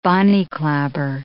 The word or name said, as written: bonny clabber